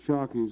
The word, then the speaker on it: Shockey: Shockey is S-H-O-C-K-E-Y. – Gail Hubblesrud, G-A-L-E. Last name is H-O-V-E-L-S-R-U-D.